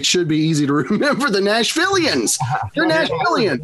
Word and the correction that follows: Nashvillains: Nashvillians, yeah. Nashvillians. I just got to flip the A and the I. – Nashvillians. That makes even more sense. I've been pronouncing it the wrong way all week.